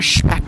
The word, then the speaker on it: Speck: I was just actually doing a little TV segment a couple weeks ago, and I was talking about Speck. Because I pronounce it the German shp instead of spa.